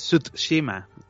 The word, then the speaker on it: Tsushima: Ahí está, es Tsushima. Ajá. La T está ahí en medio. Mucha gente se la zarta y dice el Ghost of Tsushima, ¿no? Tsushima. Está mal pronunciado.